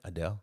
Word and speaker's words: Adele: Adele.